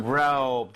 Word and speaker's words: Rome: Rome. 들어봤죠? – 그럼요, 그럼요. – Yes. R-O-M-A가 아니라 영어, 그죠? 영어식 표기는 R-O-M-E고 발음도 심지어 로마가 아니라 Rome이라고 하시면 되겠습니다.